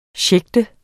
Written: sjægte

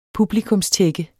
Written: publikummer

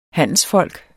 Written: HANDELSFOLK

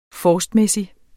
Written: forstmæssig

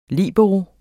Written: LIBEROEN

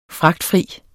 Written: fragtfri

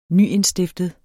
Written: nyindstiftet

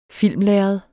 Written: filmlærred

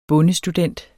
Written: bondestudent

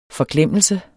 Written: FORGLEMMELSERNE

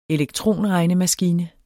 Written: elektronregnemaskine

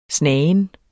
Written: snagen

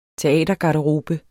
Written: teatergarderobe

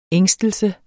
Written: ængstelse

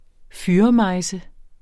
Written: fyrremejse

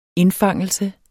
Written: indfangelse

